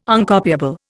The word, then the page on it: Uncopiable: Indian